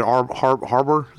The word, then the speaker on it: Harbour: And now, next year, we're getting this Hellboy reboot with David Harbour.